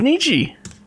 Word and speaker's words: Nietzsche: Nietzsche. – Oh my gosh. – It's Nietzsche.